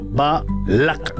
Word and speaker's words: Balac: Balac se pronuncia en hebreo Balac, con el acento en la última sílaba.